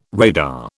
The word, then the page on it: radar: British English